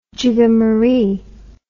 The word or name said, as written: jigamaree